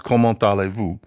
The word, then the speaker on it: comment allez-vous: The written version is one thing. Comment à les vos, but it's pronounced comment allez-vous.